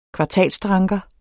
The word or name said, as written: kvartalsdranker